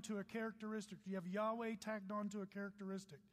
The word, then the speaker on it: Yahweh: And if you see the next slide, here's how it's pronounced. Yahweh. Everybody say Yahweh.